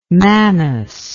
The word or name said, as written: manas